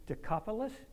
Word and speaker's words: Decapolis: So the man went away and begged, began to tell in the Decapolis, am I saying that right? – Yeah.